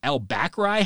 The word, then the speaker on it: El-Bakri: El Bakri? How do you pronounce that? Well, it's Brayden El-Bakri. He ended up being a really fantastic fullback for BYU in the early part of Kalani Sitake's era.